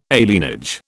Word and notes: alienage: British English

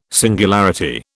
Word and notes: singularity: British English

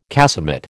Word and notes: casemate: American English

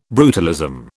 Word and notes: brutalism: British English

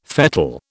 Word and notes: fettle: British English

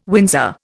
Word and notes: Windsor: Indian

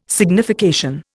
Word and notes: signification: Indian

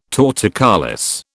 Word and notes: torticollis: British English

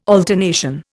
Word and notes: alternation: Indian

